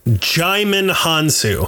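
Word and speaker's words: Djimon Hounsou: Jaimon Hansu. – Jaimon Hansu is there. – He said it rhymes with Simon.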